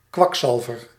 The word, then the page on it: kwakzalver: /ˈkʋɑkˌsɑlvər/